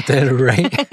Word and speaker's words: del Rey: Del Rey. Wie spricht man? – Del Rey.